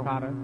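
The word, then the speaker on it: Intifada: You know, Antifada.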